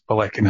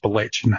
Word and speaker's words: Balechin: But when I was researching this, I remember being in my work and just making a throwaway comment that I couldn't pronounce Balekin House. I thought it was Balechin House, even though I'm Scottish and the CH is a K.